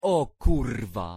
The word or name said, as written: o kurwa